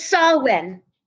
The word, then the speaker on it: Samhain: or is it Shaman? Where's Melissa when you need her to pronounce this stuff? – It's Samhain. – Samhain. Samhain. Got it.